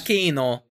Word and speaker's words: Giacchino: So, Werewolf by Night was directed by Michael Giacchino. Sorry, I don't know how to pronounce his name. But I think that's... – Giacchino.